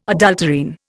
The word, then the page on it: adulterine: Indian